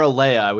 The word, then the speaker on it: Alayah: I'm being told I'm pronouncing Aaliyah wrong. – Is it Aaliyah or Aaliyah? We had this conversation. – Aaliyah. It's Aaliyah. – Okay, so I am saying it right, as gross as it sounds. – No one knows how to spell it, but I think Aaliyah is the right way to say it. – A-L-A-Y-A-H.